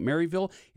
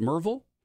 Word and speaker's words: Maryville: For those that just moved here, you're pronouncing it Maryville. It should be pronounced Merville if you've been here for your whole life.